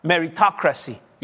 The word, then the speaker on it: meritocracy: La otra vez lo dije en inglés y lo pronuncié mal porque pensé que era, llevaba una TH, merito, pero es, en inglés es, how do you say that word? Meritocracy. Yeah, meritocracy en inglés.